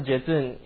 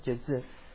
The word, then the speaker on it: Jetsun: So the word venerable in Tibetan is pronounced as Jezun. So Jezun, you can spell as J-E-T-S-U-N, Jezun.